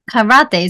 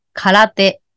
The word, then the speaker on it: karate: Karate is quite popular around the world. In Japanese, we say karate. Karate. Not like karate. Japanese say karate. It's more like flat pronunciation.